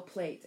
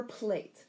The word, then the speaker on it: plait: So we're looking at P-L-A-I-T or P-L-A-T-T. Either one is correct. It's pronounced plat or plate. I like to use plate because it comes from the photography world, right?